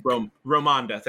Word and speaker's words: Ramonda: Queen Rwanda? I'm butchering her actual name, but Queen Ramonda, Mother. – I think. – Ramonda, thank you.